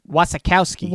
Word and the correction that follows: Wasikowska: Wasikowski. – Wasikowska. Wazowski. She's Mike Wazowski. – I'll go with that. That's, Wasikowska.